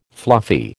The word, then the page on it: fluffy: American English